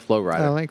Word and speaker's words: Flo Rida: Oh, I like Flo Rida. That's actually Florida. – Oh, thank you. – You're welcome. – For stating the obvious. It's Flo Space Rida. That's how you pronounce it.